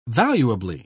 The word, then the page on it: valuably: British English